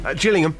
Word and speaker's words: Gillingham: Is it a soft G or a hard G, just? – Gillingham.